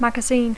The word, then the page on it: magazine: English